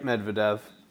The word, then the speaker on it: Medvedev: Medvedev? Is that what it is? – Yep, Medvedev.